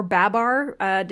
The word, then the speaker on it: Babar: And one of them is a Babar stuffed elephant. Or Babar, depending on your preferred pronunciation.